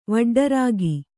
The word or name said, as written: ವಡ್ಡರಾಗಿ